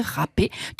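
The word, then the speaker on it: râpé: Le mot rap avec accent circonflexe vient du verbe latin raspare, qui a donné en français du Moyen-Âge raspé, qui a fini par se prononcer rapé, tandis que le S disparu était transformé en accent circonflexe.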